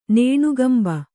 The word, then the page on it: ನೇಣುಗಂಬ: Kannada